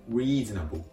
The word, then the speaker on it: reasonable: 発音のポイントは、五島の R の発音、 reasonable, reasonable。